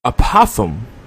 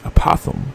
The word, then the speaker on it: apothegm: And today's word of the day is apothem. I hope I pronounced that right. Apothem. It's spelled A-P-O-T-H-E-G-M, as in Mary.